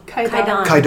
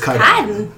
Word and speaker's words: Kaidan: Kaidan. – Because I've been around saying Kaidan. So it's Kaidan.